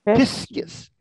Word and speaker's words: piscis: Piscis. – Now that's spelled P-I-S-C-I-S. Piscis. Okay. – Okay. – What's the word for sheep? – Ovis. – Yeah, ovis. O-V-I-S.